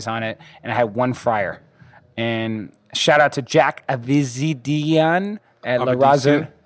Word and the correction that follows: Avedisian: Huh? Avedisian. – We're actually Armenian, so we know how to pronounce it. – Avedisian? – Yeah.